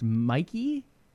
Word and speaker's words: mxey: Sticking with roster moves, John, PUBG Team Liquid has added a very good PUBG player in, I believe it's pronounced Mikey, M-X-E-Y, for those who want to try to pronounce that in Finnish.